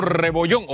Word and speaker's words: Rebollón: El doctor Arturo Rebollón, o para que no extrañe Susan Rebollón, ella le hace énfasis ahí en la última sílaba al apellido del doctor.